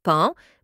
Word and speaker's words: paon: L'orthophonie, exemple, on écrit « paon » mais on prononce « pan », « pein » on écrit « examen » mais on prononce « examine », « e-g-z-a-m-e ».